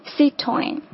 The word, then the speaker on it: Citroën: 雪铁龙正确的发音是 Citroën，它第一个音节是 C 的这样的一个音哈，后面是 toin 这样的一种感觉，所以是 Citroën。